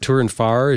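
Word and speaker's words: Tourenfahrer: Tour and Far, did I even get close on that? – Yeah, that's it.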